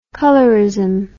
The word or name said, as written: colorism